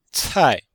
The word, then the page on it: cai: cai4